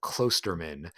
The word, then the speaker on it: Klosterman: And this author is Chuck, I always say Klosterman, but I think you pronounce it Klosterman. You spell it K-L-O-S-T-E-R-M-A-N, Chuck Klosterman.